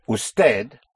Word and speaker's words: usted: Usted, spelled U-S-T-E-D. The D is hardly audible. Usted.